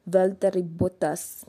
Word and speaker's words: Valtteri Bottas: But before the whole qualifying session ended, Valtteri Bottas, I actually didn't know his name is supposed to be pronounced as Valtteri Bottas. That's how he pronounces his name.